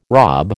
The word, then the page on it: rob: American English